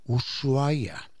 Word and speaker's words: Ushuaia: Y entonces dijo, si quiere pronunciar bien, ponga sus labios, como si fuera a besar. Ushuaia.